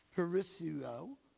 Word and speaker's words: perisseuo: This comes from the Greek word parisuo. It's spelled P-E-R-I-S-S-E-U-O, parisuo.